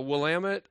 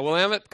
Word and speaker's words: Willamette: Actually, I was talking to Rob on the phone when I was sitting at the Willamette coffee shop, and I actually said it right. Yeah, Willamette coffee shop.